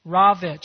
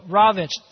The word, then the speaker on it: Rowicz: And so according to the account in the book, Polish resistor Sławomir Rowicz, can somebody help me out with that name? Rowicz. That's how you would pronounce it? Thank goodness.